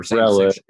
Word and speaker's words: Rowlett: Rowlett, yeah.